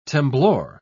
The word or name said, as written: temblor